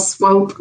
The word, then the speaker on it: Swope: And just for the record, it's Cheryl Swoop, rhymes with rope.